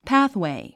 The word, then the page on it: pathway: pǽθwèi; パァスウェイ